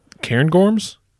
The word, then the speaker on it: Cairngorms: Cairngorms.